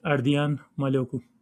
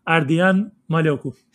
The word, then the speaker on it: Ardian Maloku: Now, in Albanian, how would you say Ardian Maloku? – It's the correct pronunciation would be Ardian Maloku. – Oh, Ardian Maloku. Okay. How's it go? What was that, Ardian? – Ardian Maloku.